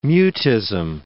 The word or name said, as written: mutism